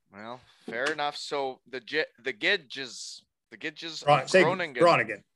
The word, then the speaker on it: Groningen: To the Gids Groningen of the Netherlands? – I believe it's Groningen. Now I can't say it's Groningen. – Groningen.